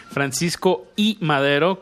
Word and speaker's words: Francisco y Madero: Francisco y Madero, como bien lo, lo acentúas ahí, Paco, así se escribe, es una eh, unión de dos nombres, no es el histórico revolucionario.